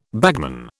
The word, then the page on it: bagman: British English